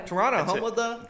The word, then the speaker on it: Toronto: That's how you pronounce that. Toronto, not Toronto.